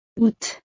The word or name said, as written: août